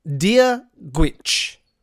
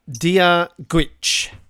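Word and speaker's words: Dia dhuit: Dear Gwich. – Can you spell it? – I can spell it. D-I-A, new word, D-H-U-I-T. Dear Gwich.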